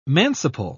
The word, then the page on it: manciple: British English